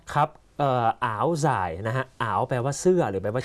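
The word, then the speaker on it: áo dài: อ๋าวสาย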